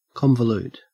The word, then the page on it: convolute: Australian English